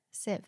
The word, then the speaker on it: sieve: You should always sieve flour. Sieve. That's how you say it, right? – Sieve. No, you don't always have to sieve flour.